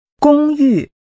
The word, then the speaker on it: 公寓: That's a high level and falling tone. Say, apartment or the apartment. – 公寓。